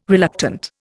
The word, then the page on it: reluctant: Indian